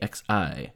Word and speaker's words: Xi: Yeah, President Xi, X-I. – That's pronounced President Xi. Okay, Xi.